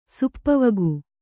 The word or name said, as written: ಸುಪ್ಪವಗು